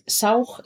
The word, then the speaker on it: Soham: Es un mantra, bueno, lo vamos a aspirar, o sea, tiene Soham, es Saug, se escribe como Saug, ¿vale? Saug, acabado en una H, como una aspiración, ¿vale?